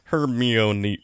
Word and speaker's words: Hermione: Hermione, that is how you say it. Because in the books, it was like Hermione.